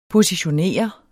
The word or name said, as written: positionere